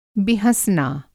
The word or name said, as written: बिहँसना